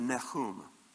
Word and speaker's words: Nahum: Who is Nahum? And that's the Hebrew pronunciation, by the way, pronunciation, by the way, and I just like the way it sounds.